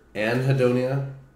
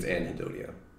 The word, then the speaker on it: anhedonia: It's either anhedonia. I think it's anhedonia. So it's just like hedonism, you know, but with an.